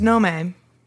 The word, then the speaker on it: Nome: No, it's Nome. – Are you sure? – I do know. – Okay. – It would be hilarious now that I said that if I was wrong, but I've... – Nome spelled N-O-M-E. – Yes. – Not like the small woodland fairy. – No, not like a gnome. Just like gnat is spelled N-A-T, not like a gnat.